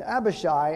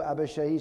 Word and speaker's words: Abishai: And Abishai, and I might change the pronunciation, his name is actually Abishai, all right? So I may interchange that. I think a lot of times we hear the preacher say Abishai, but it's pronounced Abishai in the Hebrew.